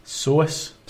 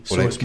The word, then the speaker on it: psoas: So, basically, I have pulled / strained my psoas muscle. – Your muscle? – Psoas. – Psoas. – Begins with a P, wee silent P. Psoas muscle.